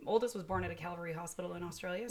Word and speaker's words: Calvary: There is a study based out of Australia from the Calvary Mater Newcastle Hospital. Probably butchered the name of that. – No, Calvary's, that's correct.